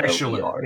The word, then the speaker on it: Naishuller: Like, is it Nightshuller? I don't know if that's how it's pronounced. But it's. Spelled N-A-I-S-H-U-L-L-E-R. – Nightshuller, yes.